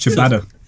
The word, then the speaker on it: ciabatta: You ever tried chia butter? – Yeah, no, garlic bread, isn't it? – No. – It's pronounced ciabatta. – Is it ciabatta? – Yeah, weirdly.